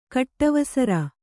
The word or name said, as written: ಕಟ್ಟವಸರ